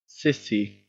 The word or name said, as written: cissie